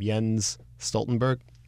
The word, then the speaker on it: Jens Stoltenberg: Jens Stoltenberg.